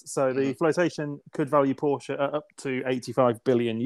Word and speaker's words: Porsche: which sounds a little bit, or Porsche, sorry. Let's get my German pronunciation right.